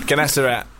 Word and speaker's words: Gennesaret: Gennesaret. That's how you say it. Gennesaret.